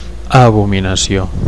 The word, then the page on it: abominacio: Catalan